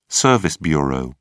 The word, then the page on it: service bureau: British English